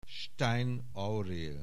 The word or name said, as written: Stein, Aurél